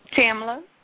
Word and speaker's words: Tamla: Tamla. – How do you spell that? – T-A-M-L-A.